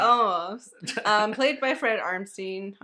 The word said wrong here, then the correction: Armisen: Armisen. Oh my god, I cannot pronounce anybody's name. – That's all right. Fred Armisen.